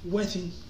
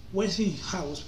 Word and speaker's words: Westinghouse: Allá se alió con alguien llamado George Westing, Westinghouse,